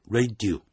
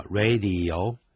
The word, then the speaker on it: radio: radio 记住是三个音节，不要念成两个音节，念成 radio 那就不对了，叫 radio。